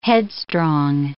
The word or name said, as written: headstrong